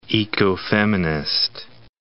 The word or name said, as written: ecofeminist